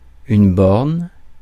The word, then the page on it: borne: /bɔʁn/; France